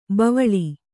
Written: ಬಾವಲಿ

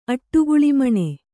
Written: ಅಟ್ಟುಗುಳಿಮಣೆ

